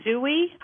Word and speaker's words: Zui: It was Zui. I don't know if I'm saying that correctly, Z-U-I. It sounds like the word oui in French.